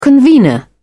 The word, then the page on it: convenor: British English